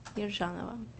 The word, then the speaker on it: Yerzhanova: Okay, Y-E-R-Z-H-A-N-O-V-A. Dear Janava.